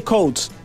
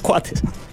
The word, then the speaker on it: Coates: decile Coates, porque hablé con el padre y él me dijo, somos de origen escocés, sí, familia escocesa, y la pronunciación correcta es Coates.